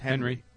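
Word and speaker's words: Henery: It's H-E-N-E-R-Y, but it's pronounced Henry. – Henry.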